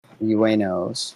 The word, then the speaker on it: Ueno: Did anybody figure out how to pronounce that? – Sakura Uenos. – Uenos. Yeah.